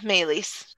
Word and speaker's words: Meleys: If is it Meleys? Is that how the dragon's name is pronounced? – Yeah, Melis.